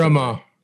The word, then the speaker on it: Harima: I'm never going to pronounce Harima. – Harima. – Rhymes with heroin.